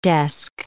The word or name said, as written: desk